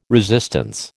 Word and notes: resistance: American English

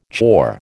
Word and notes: chore: American English

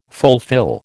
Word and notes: fulfil: American English

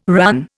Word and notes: RAN: Indian

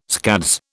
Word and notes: scads: British English

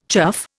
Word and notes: chuff: Indian

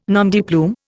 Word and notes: nom de plume: Indian

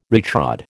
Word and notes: retrod: American English

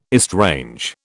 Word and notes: estrange: British English

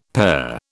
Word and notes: pair: British English

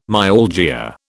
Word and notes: myalgia: British English

